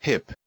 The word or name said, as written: hip